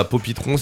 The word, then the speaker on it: Poppy Throne: Voilà, Poppy Throne, c'est comme ça se prononce. P-O-P-I-T-R-O-N.